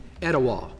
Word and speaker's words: Etowah: Etowah. – Etowah.